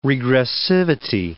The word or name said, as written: regressivity